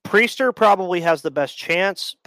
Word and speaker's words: Priester: Who's their big, Quinn, is it Quinn Priester? Is how you pronounce his name? – Priester, actually. – Priester. – Yep.